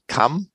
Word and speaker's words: CAM: Ja, CAM wird es ausgesprochen, Category und Angebotsmanagement.